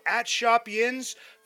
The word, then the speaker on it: Yinz: Follow @ShopYinz for new merch drops and giveaways. Order online at WW Shop Yinz, the emphasis on the two Z's, high quality comfort, lightweight materials, a new modern look for the black and gold, made for Yinz by a Yinzer.